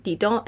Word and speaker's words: Didon: Just a quick note that Julie uses the French pronunciation Didon and Aeneas instead of the anglified Dido and Aeneas.